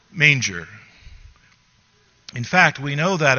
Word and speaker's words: manger: In French, M-A-N-G-E-R is pronounced manger, and it is the French verb for to eat.